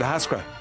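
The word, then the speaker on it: Ahascragh: That's me, Is that how you pronounce it, Ahaskra? – That's it.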